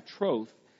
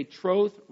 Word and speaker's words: troth: And then the second word, I plight thee my troth. They say troth rhymes with oath.